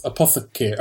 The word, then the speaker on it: apothecary: What is it? Apothecary? – Yeah. – I don't know how to pronounce it. – Apothecary. Yeah, that's it.